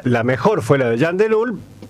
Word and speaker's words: Jan De Nul: Jan de Nul, no sé si se dice así, Jan de Nul. – Jan de Nul, sí, la empresa.